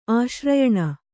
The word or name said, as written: ಆಶ್ರಯಣ